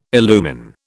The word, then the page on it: illumine: British English